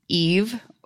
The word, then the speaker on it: Yves: Yes, I believe this is Eve. Y-V-E-S is how they spell it, but this is...